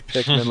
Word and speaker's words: Pikmin: And I said, "No, it's actually Pikmin, like you've been saying."